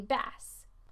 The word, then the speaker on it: bass: En otro ejemplo, con la palabra B-A-S-S, puede significar un pez o un bajo o un contrabajo, pero la pronunciación es diferente depende del significado. Por ejemplo, my father-in-law caught a bass. Bass. La palabra significa un pez. Pero my friend plays the bass. Bass significa un bajo o un contrabajo.